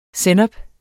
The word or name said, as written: sennep